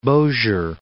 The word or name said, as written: Bossier City